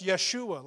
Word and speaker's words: Yeshua: His name referring to Jesus, or would have been pronounced Yeshua, like Joshua, meaning the salvation of God.